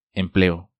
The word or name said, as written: Empleo